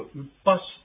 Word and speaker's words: upas: はい、ここで難しい発音はですね、このウッパシってありますね。これは雪なんです。ウッパシじゃなくてウッパシというふうに、子音なんですね。 S だけの発音です。ローマ字で発音しますと、 UPAS。ですから、ウッパシというふうに発音していただけるとよろしいと思います。